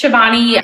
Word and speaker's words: Shivani: Yes, it's Shivani.